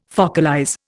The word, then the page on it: focalize: Indian